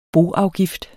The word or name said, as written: boafgift